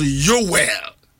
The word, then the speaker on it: Yoel: Hebrew for number pronounced Yoel. And I say Yoel.